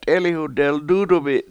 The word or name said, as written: t7EEliwhu deldUUdumi7